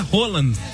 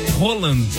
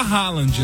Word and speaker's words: Haaland: É, a pronúncia é Roland. Roland. Mas a gente fala Haaland, né? Fica meio esquisito o Roland.